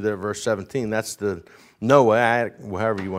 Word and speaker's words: Noahic: This is the, if you want to call it Noah covenant, I don't know how you say it, N-O-A-H-I-C, Noahic. Sounds like I got something in my back.